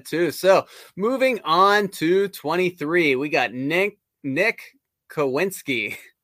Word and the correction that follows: Kwiatkowski: I hope I pronounced that right. – Kwiatkowski. – All right.